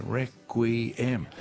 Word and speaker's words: Requiem: There's three syllables in there, Requiem.